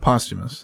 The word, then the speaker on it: posthumous: Posthumous. That's how you pronounce it.